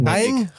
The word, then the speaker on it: Jaén: Jaén en Córdoba te zien.